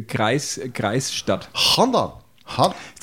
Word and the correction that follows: Handan: hast du die Aussprache hast du aber schon auch Handan. Handan.